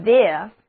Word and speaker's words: there: 首先出场的是 there， there，字母组合 ere 读作 air，这个单词的意思就是在那里或者往那里，在那方面。